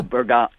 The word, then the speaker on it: Bergamo: Oh, Bergamo. It's Bergamo. – Bergamo. – You got to roll the R Bergamo Lions.